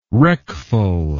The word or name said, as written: wreckful